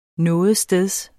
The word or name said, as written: nogetsteds